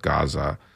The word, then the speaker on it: Gaza: In Arabic, it's pronounced Gaza. So if you hear me say it that way, that's just not me having a speech impediment. That's just me saying it in the Arabic version.